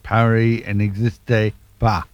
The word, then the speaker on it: Paris n'existe pas: Well, I wasn't too far out with my pronunciation, but it's Paris n'existe pas. So there you go. I got the first two words, the first word and the last word right, and I've now learned what the second one should sound like.